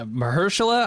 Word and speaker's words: Mahershala: Mahershala, I think is how you say his first name.